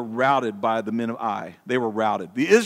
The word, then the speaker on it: Ai: The next battle is the Battle of Ai, which is spelled A-I. I think that's how you pronounce it, Ai.